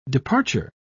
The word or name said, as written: departure